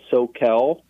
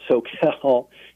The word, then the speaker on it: Soquel: Well, first of all, us, me specifically, I have one small micro business farm in an area that's called SoCal, not Southern California, but it's called SoCal in the Santa Cruz Mountains. It's spelled SOQUEL.